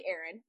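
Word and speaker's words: Erynne: Essentially, Erin. My name is spelled ridiculously. It's E-R-Y-N-N-E.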